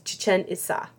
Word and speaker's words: Chichén Itzá: But the correct pronunciation is Chichén Itzá. Because there's emphasis on the last two syllables of each word.